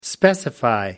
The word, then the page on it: specify: American English